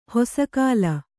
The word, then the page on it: ಹಸೆಗಲ್ಲು: hasegallu